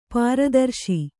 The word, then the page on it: ಪ್ರತಾರಿಸು: pratārisu